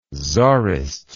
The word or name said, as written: czarist